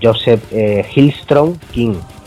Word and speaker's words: Joseph Hillstrom King: Que todo hay que decirlo, Joe es, si lo pronuncio bien, es Joseph Hillstrom King.